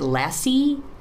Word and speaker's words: lassi: It's like, it's not a lassi, it's a lassi.